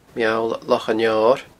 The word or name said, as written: Meall Loch an Fheoir